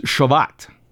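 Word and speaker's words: Shavuot: Now, the law of Moses said 7 weeks later, there was another festival that you should go to, and this one is pronounced Shavat. And it literally means weeks, because it was 7 weeks.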